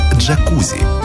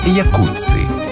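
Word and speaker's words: Jacuzzi: Это и есть то, что мы называем словом «джакузи». Хотя правильнее было бы сказать «якудзи». Именно так в действительности произносится фамилия тех самых братьев-итальянцев.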